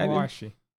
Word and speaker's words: Aoashi: So it's Aoashi? Is that how you say it? – Aoashi.